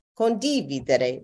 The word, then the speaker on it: condividere: And it took a very long time, for some reason, the accent, in New York, we hear a Spanish accent, the acento is on the second syllable. Mm-hmm. So I say condividere.